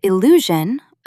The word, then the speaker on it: illusion: Pro tip, do not confuse illusion, a visual trick, with allusion, an indirect literary reference. Stress the middle syllable. Illusion. Say it out loud. Illusion.